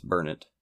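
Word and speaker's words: Burnet: I actually feel like I've been told it's Burnett. – Is it Burnett? I don't know. He kept pronouncing it Burnett. – Yeah, my Texas wife told me it's Burnett. So I grew up in Texas and I just never I never realized I was pronouncing it wrong this whole time.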